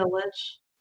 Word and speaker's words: lich: Evil wouldn't be a lich.